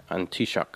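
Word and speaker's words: An Taoiseach: I should have got one of you to pronounce that word. It's Irish for Prime Minister. But the Irish language is very complicated. They say it's something like Antishak.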